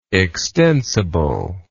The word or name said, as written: extensibleness